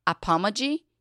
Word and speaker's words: apomagy: So I want to ask you about, and I don't know if I'm pronouncing this right, apomagy? – Yes, okay. That is perfect.